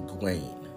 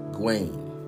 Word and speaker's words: Gawain: Way back in the late 70s, early 80s, there was this writer, Shakti Gwain. Shakti, let's like you spell it, S-H-A-K-T-I, Shakti Gwain. And I believe it was G-A-W-A-I-N.